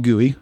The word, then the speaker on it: Gooey: It's called GUI, spelled like a GUI cinnamon roll, G-O-O-E-Y. You could find it on GitHub pretty easily, consulabs/GUI.